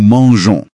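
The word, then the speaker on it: mangeons: زي الأفعال اللي أخرها جو، زي مانجي يعني يأكل، عشان نحافظ على صوت الجي زي ما هو مع نو، بنزود أو قبل الأو إن أو إس، فتبقى نو مانجون. – عشان ما تتنطقش مانجون.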